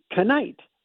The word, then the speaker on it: knight: They used to pronounce the K. – Really? – So it used to be Knite.